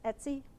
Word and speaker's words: Etsy: It's Etsy, how it's pronounced.